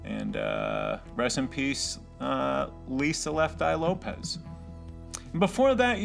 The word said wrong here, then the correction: Lopes: you heard, or actually Lopes, like Davy Lopes. It's spelled the same way as the ball player, L-O-P-E-S.